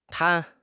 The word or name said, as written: 摊